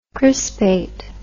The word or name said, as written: crispation